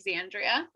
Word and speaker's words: Xandria: And it's pronounced Chloe Xandria. – Perfect. Yeah. In the beginning, I was like, it's Xandra. It's like, no, Xandria.